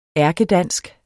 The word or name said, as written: ærkedansk